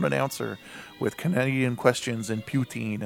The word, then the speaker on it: poutine: It's poutine.